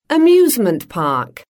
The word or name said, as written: amusement park